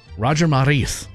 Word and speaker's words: Roger Maris: Roger Maris, am I saying that correctly? – Yeah, you did say that right. – All right. Roger Maris.